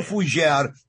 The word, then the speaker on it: Fugere: My last name is French, Fugere. My Fugere. Grandmother. – Joe Fugere.